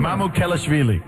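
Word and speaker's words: Mamukelashvili: Mamu Kelashvili.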